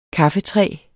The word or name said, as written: kaffetræ